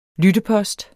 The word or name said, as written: lyttepost